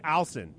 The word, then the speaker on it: Olsen: Did I say his name properly? – Olsen, yeah. It's Alson.